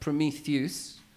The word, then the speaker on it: Prometheus: It's pronounced Prometheus.